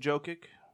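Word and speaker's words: Jokić: A couple guys put in Jokic. Did I say it right? – Yeah. Now they're the cards, buddy.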